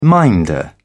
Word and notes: minder: British English

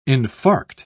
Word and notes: infarct: British English

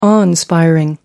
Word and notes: awe inspiring: American English

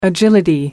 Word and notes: agility: British English

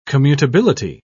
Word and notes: commutability: British English

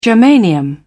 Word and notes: germanium: British English

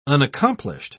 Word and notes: unaccomplished: British English

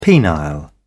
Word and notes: penile: British English

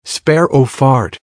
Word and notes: sparrow fart: American English

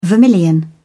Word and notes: vermilion: British English

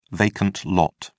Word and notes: vacant lot: British English

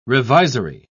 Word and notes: revisory: British English

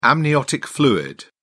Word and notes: amniotic fluid: British English

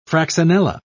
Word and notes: fraxinella: British English

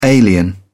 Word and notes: alien: British English

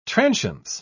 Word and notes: transience: British English

